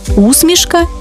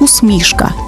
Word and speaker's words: усмішка: До речі, тут подвійний наголос – усмішка і усмішка, як у Василя Симоненка.